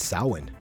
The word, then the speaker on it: Samhain: It's Samhain.